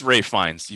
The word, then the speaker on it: Ralph Fiennes: It is Ralph Fiennes. You pronounce so it's spelled Ralph, but you say it Ralph.